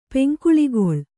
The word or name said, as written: ಪೆಂಕುಳಿಗೊಳ್